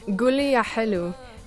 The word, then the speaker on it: Gulia Hilu: To tell us about the song we're hearing, Gulia Hilwa, am I pronouncing that right? – Gulia Hilu. This is a folk song from Iraq.